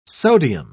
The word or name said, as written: sodium